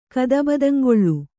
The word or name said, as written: ಕದಬದಂಗೊಳ್